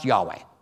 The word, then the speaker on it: Yahweh: Hebrew theologians tell us that God's name was most likely pronounced Yahweh.